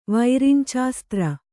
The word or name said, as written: ವೈರಿಂಚಾಸ್ತ್ರ